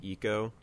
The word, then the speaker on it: Ico: The ending of Eco. – Yeah. – Or Ico, depending upon how you want to pronounce it.